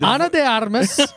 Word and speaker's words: Ana de Armas: He's Alma de Armas. – Oh, yeah, say her name right. – Ana de Armas.